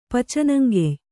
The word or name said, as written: ಪಚನಂಗೆಯ್